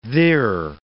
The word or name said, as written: thir